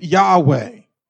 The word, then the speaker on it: Yahweh: Most scripture will see it spelled in this way, YHWH, and we pronounce it Yahweh.